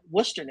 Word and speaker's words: Worcester: If you look at their options down at Worcester now, hey, I said it right.